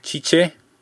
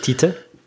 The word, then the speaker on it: Tite: Ja, und es dürfte jetzt auch der aktuelle Trainer, der äh, Chiche, habe ich mir sagen lassen, spricht man den aus. – Nicht Tite? Nein. – Ähm, Chiche. Ähm, äh, irgendwie I nach T ist mit einem SCH in der Mitte.